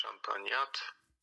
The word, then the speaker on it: Champagnat: Tak, dzisiaj w ostatnim dniu święty Marcelin Champagnat. W każdym razie tak wymawiają to imię i nazwisko polscy bracia szkolni maryści.